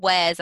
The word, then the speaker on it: Wares: It's like Wares as in any type of Wares. – Yeah. – Spelt home Wares, like the section in the supermarket.